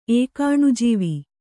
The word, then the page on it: ಏಕಾಣುಜೀವಿ: ēkāṇu jīvi